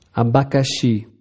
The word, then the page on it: abacaxi: Portugués Br